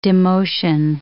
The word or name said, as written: demotion